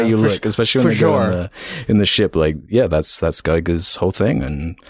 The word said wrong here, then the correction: Giger: It's, sorry, I will make a tiny correction. It's pronounced Giger, I believe.